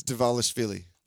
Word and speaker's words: Dvalishvili: Marab Dvalishvili. You want to pronounce that? – It's Dvalishvili.